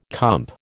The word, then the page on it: comp: American English